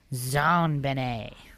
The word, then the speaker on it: JonBenet: Other facts, JonBenet was named after her father, John Bennett Ramsey, and her name is pronounced in a French style, JonBenet.